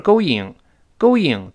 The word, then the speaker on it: going: 所以记住，不是 going。 Going 多难听啊！我们读成 going，going，人为的加一个 what，go，然后 when，when，when，你看这么读确实舒服。那跟老师再来一遍，going，going，好，而不是 going。